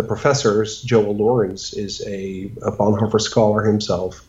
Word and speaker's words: Bonhoeffer: Of course, then it was Dietrich Bonhoeffer because I'm from the Netherlands. And it was only later that I realized that there were a lot of people who pronounced his name Bonhoeffer and that there was a very lively community in the United States that studied his work.